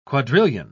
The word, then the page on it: quadrillion: British English